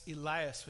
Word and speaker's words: Elias: They mishear Eli, Eli, for Elias, Elias, which is how the Jewish people pronounced Elijah's name.